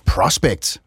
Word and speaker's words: Prospect: familiepolitisk rådgiver i Tænketanken Prospect. Prospect. – Prospect. Det er en konservativ tænketank, så jeg tænker, vi går med den danske udtale. – Vi siger prospect ja.